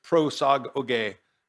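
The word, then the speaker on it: prosagoge (προσαγωγή): And I shared with you that Greek word in the Greek language, it's prosagoge, it's spelled P-R-O-S-A-G-O-G-E, prosagoge.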